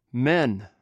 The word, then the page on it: men: greek